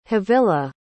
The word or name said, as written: havilah